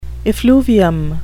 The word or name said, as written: effluvium